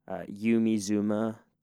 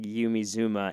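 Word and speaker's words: Yumi Zouma: Yumi Zuma, that's Y-U-M-I space Z-O-U-M-A. Now, it's not great if you pronounce it in American English, but this band is from New Zealand. So just imagine somebody saying Yumi Zuma in a New Zealand accent, and it's so much better.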